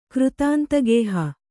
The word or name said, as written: ಕೃತಾಂತಗೇಹ